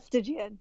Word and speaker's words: Stygian: Hard G or soft G? – Stygian.